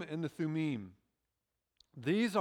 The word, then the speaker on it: Thummim: Now, I know some people say Urim and Thummim. Hebrew, the I is an long E sound, so it's Urim and Thummim.